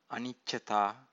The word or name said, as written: aniccata